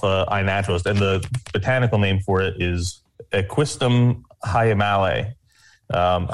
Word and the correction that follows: Equisetum: I think it's pronouncing that wrong. – equisetum. Equisetum. That makes That's a lot more sense.